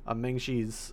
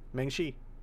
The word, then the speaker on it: Maangchi: So if you're interested in looking up Meng Xi's recipes and also their YouTube channel, it's spelled MAANGCHI. And it's going to be the YouTube channel, just that, Meng Xi.